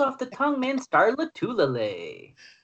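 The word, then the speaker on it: Lotulelei: Star Lotulele. I think that's how you pronounce it. – Lotulele. – Okay, you've got a better pronunciation than me.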